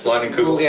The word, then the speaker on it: Leinenkrugel: Yes, I am drinking a Leinenkrugel. Is that how you pronounce it? Yes, Leinenkrugel.